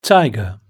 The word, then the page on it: tiger: British English